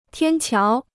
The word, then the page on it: 天桥: tiān qiáo